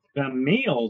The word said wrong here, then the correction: Thaumiel: That sounds like fentanyl. – Thumiel. Thaumiel. – Oh, Thumiel. Oh, okay. – It's spelled Thaumiel.